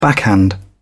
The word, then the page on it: backhand: British English